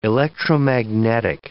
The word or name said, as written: electromagnetic